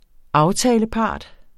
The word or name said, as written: aftalepart